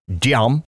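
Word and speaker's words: Diệm: Although they still managed to assassinate Diem, and I hope I'm saying that right. Uh, I'm obviously I'm not Vietnamese. It's like a uh, carpe diem. Carpe diem. Seize the day.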